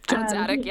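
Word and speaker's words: Jon Dziadyk: So when I did originally post it in the Northside Hub, like, this group has 63,458 members, and it is ran by John Zadike. I don't know if I'm pronouncing his last name right, but yep. John Zadike. Yep.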